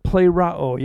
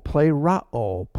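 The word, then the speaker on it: Plerao (πληρόω): It's the Greek word plerao. You might want to spell it in English this way. P-L-A-R-A-O. Plerao.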